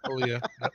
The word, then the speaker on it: Aaliyah: And then Aaliyah, however you say her name. – Aaliyah.